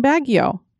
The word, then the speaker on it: Baguio: Three generations of my family have lived in Baguio. Grand folks moved there around 1955. So it was nice hearing our hometown get brought up. And yes, you pronounced it correctly."